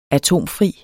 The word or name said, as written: atomfri